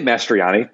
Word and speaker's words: Mastriani: I say Mastriani, but there's a real jumble of vowels in there. I don't hold it against anybody. Like, an O is fine, O-I, that's okay. But when you get to O-I-A, I mean, really, it's anything goes.